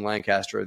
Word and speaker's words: Lancaster: We bought that in Lancaster, Pennsylvania, or as they say in Lancaster, they say Lancaster, kind of like one syllable. You got to let it all roll out. Just let it just fall out of your mouth, right?